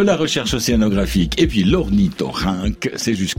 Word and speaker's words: ornithorynque: Bon, alors, je vais déjà vous dire comment ça se prononce. C'est ouais. L'ornithorynque. Alors, comment ça s'écrit, moi je le sais déjà, Anne, Cécile. – O-R-N-I-Y quelque part.